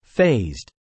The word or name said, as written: phased